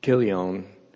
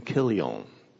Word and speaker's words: Chilion: Now, depending on what version you're reading, you can read it, and some will spell Chilion's name K-I-L-I-O-N. Some will spell it C-H-I-L-I-O-N. But that C-H is pronounced the way we would pronounce the C-H in the word of the famous composer Bach. So it's Chilion and not Chilion.